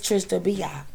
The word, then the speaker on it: Trish tha Bia: Say Trish the bitch. – No, I didn't. It's Trish to be, because there nobody say bitch to me, because I'm about to drag them hoes.